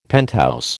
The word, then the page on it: penthouse: American English